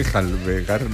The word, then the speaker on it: enjalbegar: Para que volviera a enjabelgar, ¿cómo se dice? Enjalbegar la pared.